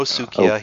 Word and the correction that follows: Suika: he's not dead. She kept like, he's dead! – It's Suika. – Oh yeah, I always say her name wrong. Suika.